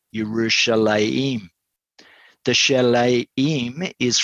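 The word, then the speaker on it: Yerushalayim (ירושלים): The place name Jerusalem, pronounced Yerushalayim in Hebrew, is a combination of two words.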